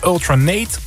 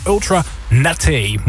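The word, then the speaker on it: Ultra Naté: Ja, want er staat een accent aigu op de E. Dus het is niet Ultra Nate, maar het is Ultra Nate.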